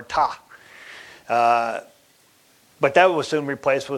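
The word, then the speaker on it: Ptah: It's A-M-U-N. And that all gods are three, Amun, Re, and Ta. Ta spelled with a P, P-T-A-H.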